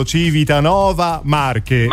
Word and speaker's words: Civitanova Marche: Ho detto bene, vero? Civitanova Marche. – Marche, perfetto. – Marche, sì.